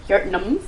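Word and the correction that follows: Jötunn: Jotnums. Is that how you say it? – Jotuns. – Jotuns. Oh, what the fuck was that going?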